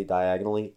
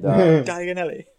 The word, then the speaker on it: Diagon Alley: I almost say diagonally every time. – Oh, I'm waiting for that. – And Diagon Alley.